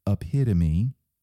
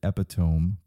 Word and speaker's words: epitome: If you were to tell me what the epitome, big word, if you've ever seen it, you might have pronounced it epitome, but that's not how it's pronounced.